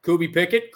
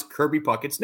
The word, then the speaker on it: Puckett: Kirby Pickett, Creepy Pockets, Kent Herbeck. Those are three different ways to mispronounce Kirby Puckett's name.